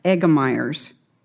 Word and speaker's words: Egermeier's: It is pronounced, it's spelled E-G-E-R-M-E-I-E-R-S, Egermeyer's.